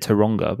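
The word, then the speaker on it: Turanga: Well, I think they pronounce it Taronga, but it's spelt Taranga.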